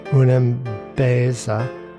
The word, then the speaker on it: Unam Beza: Unam Beza is how it's pronounced,